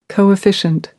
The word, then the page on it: coefficient: American English